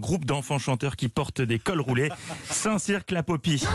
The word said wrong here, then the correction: Saint-Cirq-Lapopie: Comment Saint-Cyr la Popie. Oui, c'est ça.